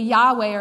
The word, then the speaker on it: Yahweh: And the majority opinion would say Yahweh or something along those lines is how it was originally pronounced.